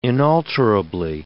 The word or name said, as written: inalterably